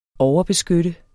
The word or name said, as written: overbeskytte